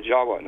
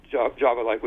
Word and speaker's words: Jawa: Furthermore, appending the suffix of T-I-S, the word Atlantis, it also is spelled T-I-V or pronounced T-I-W. The V's are pronounced as W's in Dravida, such as in Java. The word in Java, the island of Java, you know, is pronounced Jawa. Actually, Indonesians will pronounce it Jawa, not Java like we do.